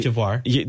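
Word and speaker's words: Côte d'Ivoire: Cote d'Ivory. – Cote d'Ivory. – No. What'd you say? – Cote d'Ivory. – So this is going to be Cote d'Ivoire.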